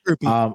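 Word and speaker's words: Grupe: So Blake Groupie, I don't know how to say his last name. I don't want to butcher it, but it's Groupie.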